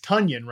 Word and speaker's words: Tonyan: Like he's Tunyon, right? Tunyon the Funyon is how you actually officially pronounce his name.